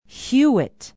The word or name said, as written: hewitt